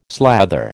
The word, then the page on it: slather: American English